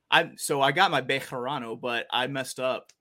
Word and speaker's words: Bejarano: Did I say that right? Bejarano. – Yeah, that's good.